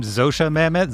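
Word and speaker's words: Zosia Mamet: Zosha Mamet.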